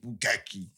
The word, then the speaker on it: Bukkake: You know that B-U-K-A-K-A-U-U-K-U? It's a bit of K's. Say, yeah, my man just pronounced it, Bukkake.